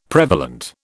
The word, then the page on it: prevalent: British English